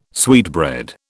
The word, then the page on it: sweetbread: British English